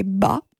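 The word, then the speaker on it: ba (八): En mandarin, la prononciation du numéro 8 est ba.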